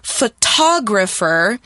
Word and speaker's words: photographer: So in photographer, you're putting the stress on the ta, and in photographing, you're putting the stress in the graph.